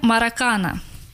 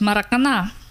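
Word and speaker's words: Maracanã: Как мы его называем, Маракана. Но правильно говорить Маракана.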